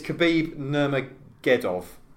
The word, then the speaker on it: Khabib Nurmagomedov: How do you pronounce Khabib Nurmagomedov? – It's Khabib Nurmagomedov.